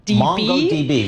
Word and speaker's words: MongoDB: Yep, of course, Meta, M-E-T-A. It's actually slightly down after a surge last week. We see Meta tapped, I don't know how to say the company name, MongoDB. – MongoDB, very good.